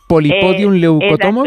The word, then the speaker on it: Polypodium leucotomos: Yo ya aquí me estoy viendo muy arriba, también a ver si lo digo bien. Polypodium leucotomos, ¿no? ¿Es así? ¿Está bien dicho? Polypodium leucotomos. – Exacto, sí.